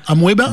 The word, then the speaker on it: Amoeba: Je sais pas comment on le prononce. A-M-O-E-B-A. Amoeba ? – Amoeba. Ouais.